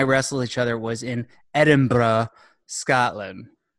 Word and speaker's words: Edinburgh: Ooh, ooh, how about that pronunciation? – Edinburgh, is that not right? Yeah, it's right.